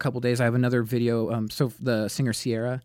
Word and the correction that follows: Ciara: mm-hmm. Ciara, I always say it wrong.